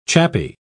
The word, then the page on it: chappy: British English